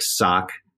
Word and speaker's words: Saucony: But then it occurred to us, you don't know how to say Saucony, which a lot of people don't. – Right. – People think it's Saucony. Or, and so we said, so just so you know, and we phonetically spelled it Sock-A-Knee.